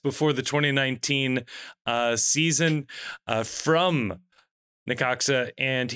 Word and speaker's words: Necaxa: That takes us over to South 4, where we have FC Dallas, Charlotte FC, and Necaxa, which is how you pronounce this. I feel pretty confident in saying. – I think so. I don't know. I haven't looked it up. – N-E-C-A-X-A. Necaxa is how I am choosing to pronounce this.